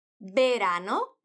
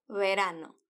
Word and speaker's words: verano: E a maioria das pessoas que falam espanhol pronunciam esse V do começo com os sons do B do espanhol. Verano ou verano. Mas a Maria fala com um som igual ao V do português.